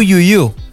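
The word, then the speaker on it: Tu Youyou: Pues bien, vamos a irnos a China con Tu Yu Yu. Justamente como tú podría ser. Tú podría ser, no, se llama Tu Yu Yu. O Yu Yu, creo que es la pronunciación un poco más cercana.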